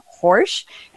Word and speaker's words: Horejs: We have gallery owner Jason Horejs, and he told me his last name rhymes with Porsche, even though it's spelled H-O-R-E-J-S.